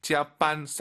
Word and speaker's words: 加班: 짜반. 쌍비읍보다는 약간 비읍 느낌으로 힘 빼시고 짜반 하면 좋을 것 같아요.